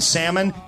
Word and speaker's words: salmon: So if you go to the restaurant and the waitress pronounces the L in salmon, it's not going to be a good meal.